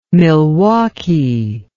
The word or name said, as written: milwaukie